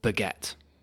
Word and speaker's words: baguette: So like the word baguette, emphasis is on, the stress is on the second syllable.